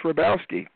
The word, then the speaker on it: Hrabowski: The H is silent. Just Hrabowski.